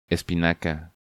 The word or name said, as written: Espinaca